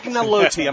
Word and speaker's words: agnolotti: Agnolotti. I'm going to pronounce it. It's agnolotti.